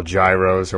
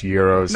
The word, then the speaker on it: gyros: Uh, people what people call gyros or whatever. They're pronounced euros.